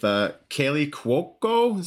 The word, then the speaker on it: Kaley Cuoco: With Kaley Cuoco, is that how you pronounce her name? – Mm-hmm.